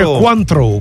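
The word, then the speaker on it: Cointreau: Ma chi gira con i cioccolatini al Contrô? Che manco più il Contrô sa di avere. – Si dice Quantrô. – Quantrô, manco so come si pronuncia.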